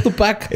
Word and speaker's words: Tupac: el pequeño Tupac. Era el último inca de Perú, Tupac Amaru. Órale. Tupac, porque tiene acento en la U.